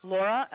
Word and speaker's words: Laura: Laura. – Can you spell it for me? – Laura, L-A-U-R-A.